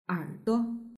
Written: erduo

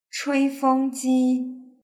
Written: chuifengji

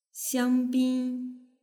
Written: xiangbin

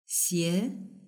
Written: xie

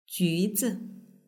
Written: juzi

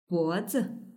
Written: bozi

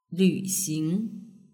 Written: lvxing

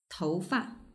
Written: toufa

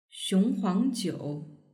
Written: xionghuangjiu

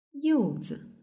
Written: youzi

